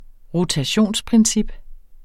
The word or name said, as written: rotationsprincip